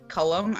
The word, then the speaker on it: Cullum: It's Cullum.